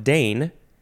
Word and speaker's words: deign: It's pronounced Dane. Dane, but it is spelled D-E-I-G-N. It's like the word rain, R-E-I-G-N, which is like you have a whole reign over a thing.